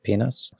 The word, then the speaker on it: Pines: The best English translation that I'm aware of is by Pinhas. That's spelled P-I-N-E-S, but it's pronounced Pinhas, since we're on that anyway.